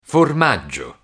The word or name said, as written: formaggio